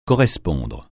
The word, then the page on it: correspondre: francais